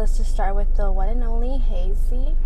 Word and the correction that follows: Haze: That's how you pronounce her name? – Haze. Just Haze, yeah.